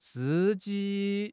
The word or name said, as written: si1 ji1